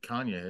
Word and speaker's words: Kanye: Cayenne. I never know his name. How do you say first? – Cayenne Pepper West. – Cayenne Pepper West. – Oh, she was married to Kanye.